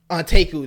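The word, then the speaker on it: Anteiku: Now, granted, they are still, you know, there's a group that Kaneki first joins called the, I'm going to butcher the name. And I think Anteku. – Anteku, there we go.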